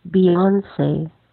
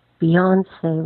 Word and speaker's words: Beyoncé: Zumstein asked us in the forum, what's the correct pronunciation of the name of the singer Beyoncé? – According to David Letterman, it's pronounced Beyoncé, Beyoncé, with the accent on the second syllable.